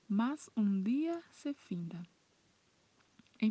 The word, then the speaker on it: mas: Esta frase, é muito importante prestar atenção que o mas não se pronuncia como no português, que falamos mais, é mas.